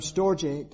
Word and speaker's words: storge: If you look at it, it looks like storge, S-T-O-R-G-E, but it's pronounced storge.